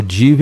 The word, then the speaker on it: Div: É arroba pizzaria da família Div, é o Div é mudo, viu? O V é mudo, tá?